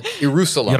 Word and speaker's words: Ierusalem: This game is called Ierusalem. – Ierusalem. – Anno Domini, okay? What? Ierusalem is spelled like Jerusalem with an I.